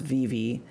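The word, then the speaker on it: Vevay: And in Indiana, it enters along the Ohio River near just a little bit south of the town of Vivi, which is spelled like Vive, pronounced Vivi.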